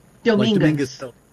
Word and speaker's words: Dominguez: You can say Dominguez. – Dominguez. – Like Dominguez Hills in California.